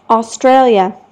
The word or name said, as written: australia